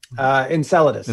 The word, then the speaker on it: Enceladus: It's called Enceladus. Am I saying that correctly? – Enceladus.